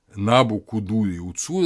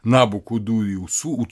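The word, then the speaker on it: Набу-Кудурри-Уцур: Набу-Кудури-Уцур – это вот правильное произношение того имени, которое евреи слышали, как Навуходоносор. Набу-Кудури-Уцур – это Набу, защити мои пределы.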